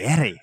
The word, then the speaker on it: Fieri: Fieri. – That's how they say it. – Yeah, no, I've just never heard it pronounced. – Guy that. – Fiero. – Fieri. – Like the shitty Pontiac.